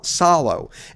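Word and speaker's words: Salò: I'm going to go with Salo. I want to pronounce it right. – Salo. – S-A-L-O.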